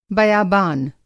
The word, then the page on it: बयाबान: Hindi